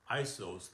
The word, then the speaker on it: Iesous: I-E-S-O-U-S, and it's pronounced Iesous, and that was the name that was given to Jesus. And it's pronounced J-E-S-O-U-S, which would be Jesus in the Greek.